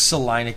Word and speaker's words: Salina: It's Salina, Kansas, and you know, a lot of those who aren't familiar with the Missouri Valley, Salina is a small town.